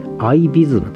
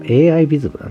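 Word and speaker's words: AIVISM: えー、そして、えー、っと、タイトルは、アメリカンの民泊体験と思考する 4 証言と、アイビズムと読みます。これ、アイビズムな、 AI ビズムだな。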